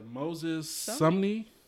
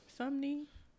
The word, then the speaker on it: Sumney: How do you pronounce it? Moses what? – Yeah, Moses Sumney. – Sumney? – Yeah.